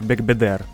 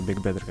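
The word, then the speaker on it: Бегбедер: Это как ударение на последний слог в французских словах или что-то такое, в именах. – Угу. – Так, э, Фредерик Бекбедер, а не Бекбедер, как я говорил.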